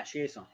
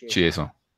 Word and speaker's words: Chiasson: C-H-I-A-S-S-L-N? – Yeah, Chiasson, yeah. – Chiasson. Okay. I didn't know he was French. – I just think that's just the way I've always pronounced it.